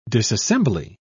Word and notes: disassembly: British English

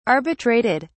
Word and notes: arbitrated: British English

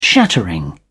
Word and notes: shattering: British English

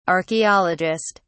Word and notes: archeologist: British English

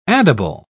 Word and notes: addible: British English

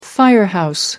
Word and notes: firehouse: British English

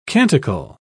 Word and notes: canticle: British English

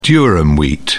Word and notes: durum wheat: British English

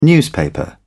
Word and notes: newspaper: British English

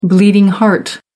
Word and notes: bleeding heart: American English

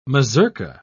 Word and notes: mazurka: British English

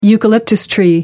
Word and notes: eucalyptus: American English

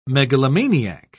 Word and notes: megalomaniac: British English

